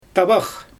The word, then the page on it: tabax: Xyzyl